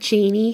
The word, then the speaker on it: genie: The word genie has a long E vowel in the first syllable.